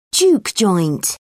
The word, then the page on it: juke joint: British English